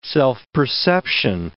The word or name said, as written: self-perception